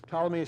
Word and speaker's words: Ptolemy: Ptolemy is spelled with a P, but the P is silent.